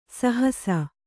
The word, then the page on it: ಸಹಸ: sahasa